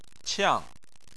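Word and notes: qiang: tone 4

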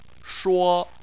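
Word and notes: shuo1: tone 1

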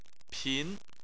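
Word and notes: pin: tone 2

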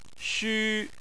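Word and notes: xu: tone 1

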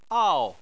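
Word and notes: ao: tone 4

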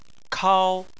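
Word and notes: kao: tone 1

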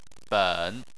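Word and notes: ben: tone 3

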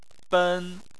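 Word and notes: ben: tone 1

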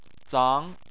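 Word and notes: zang: tone 2

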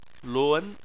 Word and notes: lun: 2

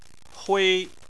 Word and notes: hui: 1

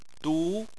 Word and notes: du: 2

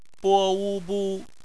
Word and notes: bu: bpmf

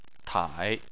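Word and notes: tai: tone 3

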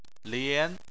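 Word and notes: lian: tone 2

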